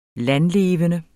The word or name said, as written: landlevende